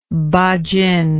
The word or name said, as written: ba jin